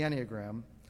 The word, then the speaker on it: Enneagram: I was first introduced to the Enneagram, yes, it's pronounced the Enneagram, a handful of years ago during a time of study and reflection that the young adults conducted here at Grace.